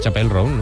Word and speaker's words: Chappell Roan: Chappell Roan, ¿no? – Ella misma nos explica cómo se pronuncia.